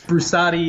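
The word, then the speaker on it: Brusatte: Yes, Brusate. It doesn't matter. Everybody pronounces it a thousand different ways. It's a weird name, but Brusate is how we say it, Italian name.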